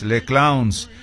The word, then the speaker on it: Les Clowns: Bueno, en francés, está escrito en francés, Les Clowns, y ya Clowns está pronunciado en inglés.